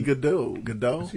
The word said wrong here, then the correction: Gudex: Gudex. – Nicki Gudex.